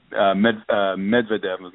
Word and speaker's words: Medvedev: Certainly the acquisition of Medvedev, teaching me how to pronounce his name properly, and the middle part of the name is supposed to be emphasized, obviously, but I can't get my tongue to roll that way, and I'm trying.